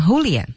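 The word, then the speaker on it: Julien: 大家注意他们这种语言啊，它是 J-U-L-R-E-N，但是它读要读成 Hulian，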